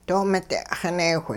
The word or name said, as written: doo-mide'xineewh